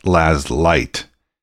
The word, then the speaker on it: Lazleitt: The name of the band is actually spelled L-A-Z-L-E-I-T-T, but pronounced Laz Light.